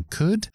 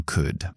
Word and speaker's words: could: esa L que llevan esas palabras eh, no podéis resistir en pronunciarlo, sin embargo no se oye, es una letra muda totalmente. Entonces, vamos a, empezar con, con could.